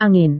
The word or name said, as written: angin